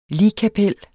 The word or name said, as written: ligkapel